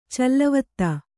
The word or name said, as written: ಚಲ್ಲವತ್ತ